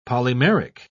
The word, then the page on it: polymeric: British English